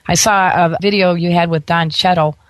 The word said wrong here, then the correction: Cheadle: so Don Cheadle,